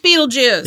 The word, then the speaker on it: Betelgeuse: Betelgeuse. It's pronounced Beetlejuice.